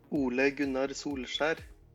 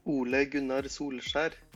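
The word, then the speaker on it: Ole Gunnar Solskjær: Nah, ini promo sebelum kita ngobrol lebih jauh dengerin dulu pronunciation yang benar untuk manggil Ole ya. Ole Gunnar Solskjaer. Udah dengerin? Udah, udah. Sekali lagi. Ole Gunnar Solskjaer.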